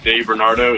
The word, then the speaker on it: De Bernardo: Do you get Hugo a lot? Like with the silent H? – I get Hugo. I get Hugo. I get De Bernardo. De Bernardino. But De Bernardo is perfect.